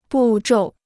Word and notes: 步骤: bù zhòu

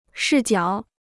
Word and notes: 视角: shì jiǎo

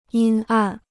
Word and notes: 阴暗: yīn àn

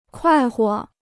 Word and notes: 快活: kuài huo

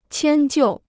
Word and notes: 迁就: qiān jiù

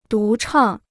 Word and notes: 独唱: dú chàng

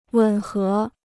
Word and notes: 吻合: wěn hé

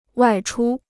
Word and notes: 外出: wài chū

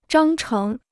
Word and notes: 章程: zhāng chéng

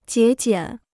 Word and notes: 节俭: jié jiǎn

